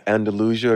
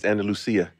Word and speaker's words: Andalusia: Andalusia, Alabama, that's how you say it? – Andalusia. Are you from Spain? It's Andalusia.